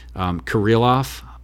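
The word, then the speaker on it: Kirilloff: Alex, I forget how you pronounce his last name, Kirillov. I probably botched that. – Sounds right.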